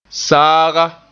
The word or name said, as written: saaga